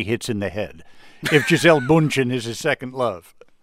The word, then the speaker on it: Gisele Bündchen: He and Gisele Bündchen, am I pronouncing that correctly? – Very well.